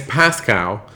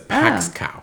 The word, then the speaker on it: Pascow: it's Pascow. Okay, so but the that. girl oh. mispronounces it as Paxcow.